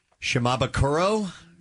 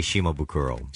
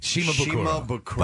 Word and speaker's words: Shimabukuro: How do you say it, Jake? – Well, I say Shimabukuro. – Shimabukuro.